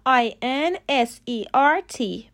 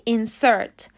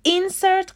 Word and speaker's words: insert: تلفظ صحیح insert insert غلطه یه نکته دیگه هم که میخواستم بهتون بگم اینه که استرس در لغت insert روی سیلاب دومه insert غلطه باید بگیم insert